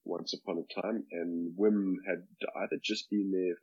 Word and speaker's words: Wim: Not a direct one, and you pronounce it Wim, by the way. He's quite particular about that. – Oh, sorry, yeah. Wim.